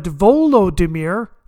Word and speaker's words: Volodymyr: Vladimir the Great is a Russian name, but Volodymyr is the Ukrainian pronunciation.